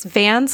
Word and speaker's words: Vans: It's Vans like the shoes and then Evers.